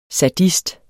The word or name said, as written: SADIST